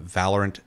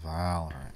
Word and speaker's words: Valorant: The nominees are EVO 2022, 2022 League of Legends World Championship, PGL Major Antwerp 2022, the 2022 Midseason Invitational, and Valorant Champions 2022. Sorry, I didn't read those before. I'm very tired. – I said it wrong. It's Valorant.